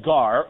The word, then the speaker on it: gar: And the Greek word, this little Greek word here, is pronounced gar, and it means for, F-O-R.